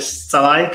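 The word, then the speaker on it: Szalai: E volevo anche sottolineare a proposito dell'Ungheria la grande prestazione di Salai, che è salito in cattedra dopo l'infortunio dell'altro attaccante, Salai, che si scrive con la SZ se non sbaglio.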